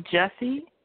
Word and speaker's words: Jussie: Is that how you pronounce it? Smollett? Smollett? – Smollett. Yeah. – Is it? – Yeah. – Is it Jussie Smollett? Smollett? I don't know. – It's Jussie. Jussie Smollett.